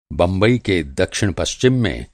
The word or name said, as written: दक्षिण-पश्चिम